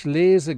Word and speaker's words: lese: Ich lese gerne. That's got a long E. Lese.